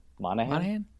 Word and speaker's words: Monaghan: Did I say his name? – Monaghan.